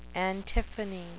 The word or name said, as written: antiphony